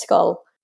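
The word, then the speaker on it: Schull: it's spelled S, I know, S-C-H-U-L-L, I think. It's Skull. And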